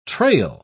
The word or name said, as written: trail